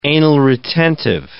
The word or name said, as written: anal-retentive